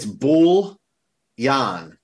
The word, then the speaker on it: bouillon: It's bull yan, like wool, but with a B.